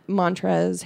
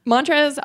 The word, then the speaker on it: Montrezl: Montrezl also has an L on the end of his first name. – Mm-hmm. – Don't pronounce that? – Most people don't. – Okay. – And I don't think he does. – Okay, superfluous L.